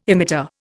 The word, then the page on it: emitter: Indian